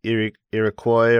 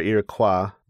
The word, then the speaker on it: Iroquois: Actually, in the Wikipedia entry, it's pronounced either way, Iroquois or Iroquois.